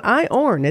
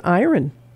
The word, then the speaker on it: iron: It's spelled iron. – Iron. – And it's not. It's not iorn, it's iron. – Iron.